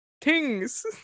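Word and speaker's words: Tyngsboro: Tings.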